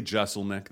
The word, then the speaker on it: Jeselnik: Is it Jeselnik or Jeselnik? – It's funny. It's technically Jeselnik. That's what I use.